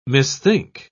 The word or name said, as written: misthink